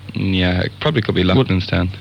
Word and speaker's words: Loughlinstown: That's in Lushvini, I think the name of that. Excuse my pronunciation on that. County Dublin. Lughshiny. Can you elaborate on that at all? – That's Loughlinstown, I think.